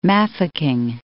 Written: Mafikeng

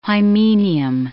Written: hymenium

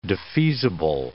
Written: defeasible

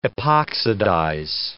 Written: epoxidize

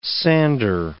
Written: sander

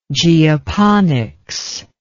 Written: geoponics

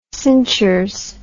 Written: cinchers

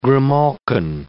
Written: grimalkin

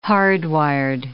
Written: hardwired

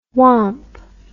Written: womp

